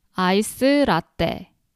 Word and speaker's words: 아이스 라떼: And iced latte in Korean is 아이스 라떼. So this is a Korean way of pronouncing iced latte.